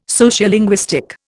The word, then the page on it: Sociolinguistic: Indian